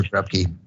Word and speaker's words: Krupke: So anyway, and I said it wrong, by the way, Krup You, of course, but hey, Officer Krupke, Krup You.